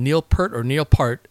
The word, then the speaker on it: Neil Peart: It's not in my show notes, but Neil Peart, I think that's how you say his name, Neil Peart, the drummer of the rock group Rush, he said he's one of the best drummers in rock and roll, if not music.